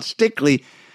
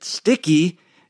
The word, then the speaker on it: Stickley: Well, not everything, but I know a lot more about Stickley than I did when I was pronouncing it sticky in the store.